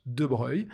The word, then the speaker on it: de Broglie: Alors ça s'écrit de Broglie, mais ça se prononce de Breuil.